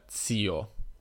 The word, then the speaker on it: zio: Questa è la mia pronuncia della parola zio, perché a dire la verità nell'italiano standard, cioè lo standard dice che questa parola si pronuncia zio. Se t- cercate la trascrizione IPA di zio troverete, il, troverete TS, che vuol dire z. Mm, io però dico zio perché in Piemonte si dice zio, tutti dicono z.